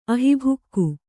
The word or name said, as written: ಅಹಿಭುಕ್ಕು